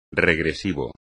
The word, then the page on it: regresivo: espagnol